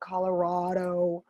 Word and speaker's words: Colorado: The local people of Colorado pronounce it rad because it is rad.